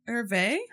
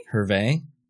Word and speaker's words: Hervé: It's like Hef or Herve? – Isn't it Hervé? – Hervé.